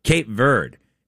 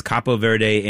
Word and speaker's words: Cape Verde: Cape Verde beating, and that is the way you pronounce it. It's Cabo Verde in Spanish, but according to all the sites I looked at in English, you're supposed to say Cape Verde.